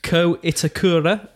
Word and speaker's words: Ko Itakura: Um, Ko Itakura, um, the Japanese player from absolutely, and that was good pronunciation.